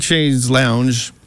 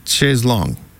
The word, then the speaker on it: Chaise Longue: And then another one from the first album, Chaise Longue, which looks like Chaise Lounge. However, I found out eventually that in England, for some reason, they pronounce it Chaise Longue.